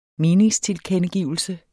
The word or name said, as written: meningstilkendegivelse